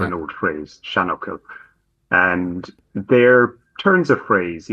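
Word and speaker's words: seanfhocal: Oh, these are what are called shanuckle. So shan being old, and buckle is actually just a word.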